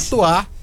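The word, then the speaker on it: Chez toi: Chetoar, underline, em seu lugar. Chetoar se escreve chess, Chez, e Toar se escreve Toi.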